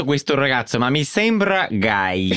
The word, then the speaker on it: gay: Leslie, the word for gay in Italian is just gay with an Italian accent. So it's just guy.